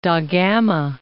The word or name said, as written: Gama, da